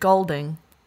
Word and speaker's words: Goulding: Ellie Goulding. Is that how you pronounce it? – Goulding. – All right, Ellie Goulding.